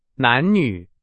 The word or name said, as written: 男女